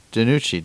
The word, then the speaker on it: DiNucci: So I traded a 2022 third to get Ben DiNucci today. You say it way cooler than me, though. But yeah, I did. – DiNucci. You gotta put the Italian accent on the end of it.